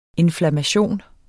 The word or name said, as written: INFLAMMATIONS